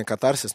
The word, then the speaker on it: катарсис: Вы потом посмотрите, проникнетесь, э, у вас будет эстетическое наслаждение, катарсис и все такое. А, некоторые считают, что правильно катарсис, но можно и так, и так.